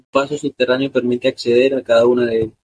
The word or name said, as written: acceder